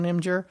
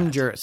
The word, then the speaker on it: Imgur: But there is no vowel between the M and the G. – I fully realize that. Imgur. Some people probably say Imgur, like it's the whole GIF argument.